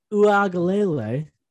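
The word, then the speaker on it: Uiagalelei: But I'll tell you about this guy, DJU, the man whose name shall be pronounced properly, DJ Uagalele. That sounded pretty bad, but it's correct. There's no N in there, folks.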